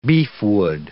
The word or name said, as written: beefwood